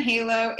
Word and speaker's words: Halo: My name is spelled R-Y-A-N-N. So there's 2 N's. And then Halo, H-A-L-O.